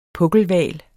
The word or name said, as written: pukkelhval